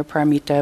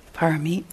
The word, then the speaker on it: Parami: Paramita of renunciation. And how do you say it in Pali? – Parami.